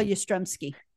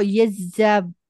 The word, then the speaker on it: Yastrzemski: And one of them, Carl Yastrzemski. – That's right. And they all say the name correctly. – Carl Yastrzemski.